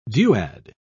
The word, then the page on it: duad: British English